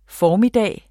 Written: formiddag

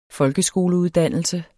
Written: folkeskoleuddannelse

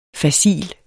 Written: facil

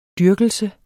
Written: dyrkelse

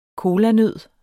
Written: kolanød

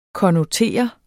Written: konnotere

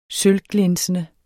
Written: sølvglinsende

